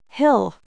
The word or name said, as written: hill